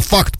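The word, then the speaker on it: FACT: Я хочу полную аббревиатуру, потому что читается как слово факт по-английски, да, факт.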